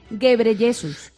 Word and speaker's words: Ghebreyesus: Pues en vez de Pedro, Tedro. Y en lugar de decirlo bien, pues se dice Ghebreyesus. – Ghebreyesus.